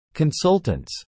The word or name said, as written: consultants